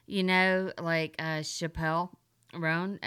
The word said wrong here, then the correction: Chappell Roan: Did I say her name? – Chappell Roan. – Chappell. What? I'm thinking Dave Chappelle.